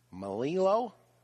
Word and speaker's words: Melillo: Nicholas Melillo, M-E-L-I-L-L-O.